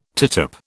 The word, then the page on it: tittup: British English